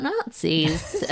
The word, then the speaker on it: nazi: No, well, it's just a sort of strange coincidence that the word for coconut in Swahili is spelled N-A-Z-I, although it's pronounced Nazi, just like Winston Churchill used to say.